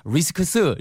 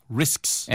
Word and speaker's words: risks: 근데 S가 붙어서 발음이 쉽지 않아요. risks 이렇게 안 들리고요. – risks. – 네, risks. risks. 이렇게 들려요. 아시겠죠? – I know the risks.